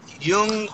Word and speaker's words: Jung: How, do you, how does he say his name? Young. – Jung. – Oh, oh yes. Call J uh, U N G. – Carl Jung. – Carl Jung. Jung. That's yes. how you say it.